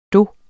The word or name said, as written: do